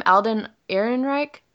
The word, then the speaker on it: Alden Ehrenreich: Alden Ehrenreich? – Yeah.